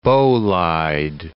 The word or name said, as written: bolide